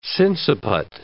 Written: sinciput

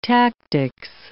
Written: tactics

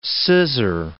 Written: scissor

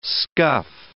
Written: scuff

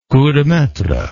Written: coup de maitre

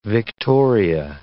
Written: Victoria

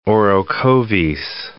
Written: Orocovis

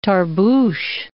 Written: tarbush

